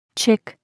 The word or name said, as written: chick